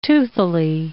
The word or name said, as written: toothily